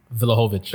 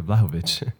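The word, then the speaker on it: Vlahović: Why'd you say his name like that? – Now you say his name? – Kind of disrespectful. Vlahovic. – Isn't it Vlahovic? – Disrespectful. Right. Yeah, Vlahovic. I didn't know that.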